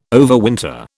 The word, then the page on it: overwinter: British English